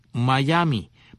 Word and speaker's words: Mayaimi: Miami, que se escribe M-A-Y-A-I-M-I, Miami, Miami.